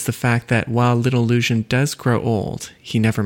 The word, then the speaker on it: Luzhin: I should say Luzhin Defense because that's how the author explains it should be pronounced.